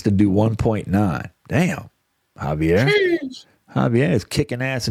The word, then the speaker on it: Javier: The guy's name, I don't know if it's Javier or Haver. I don't know. – It looks like Javier. – Is that how you say it? You're looking at That's how you say it. Javier.